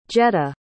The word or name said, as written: Jetta